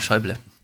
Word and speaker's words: Schäuble: mal jemand, aber da bitte eine Sprachdatei schicken, weil wir wissen auch nicht, wie es ausgesprochen wird. Schöble. – Äh, genau, Schäuble.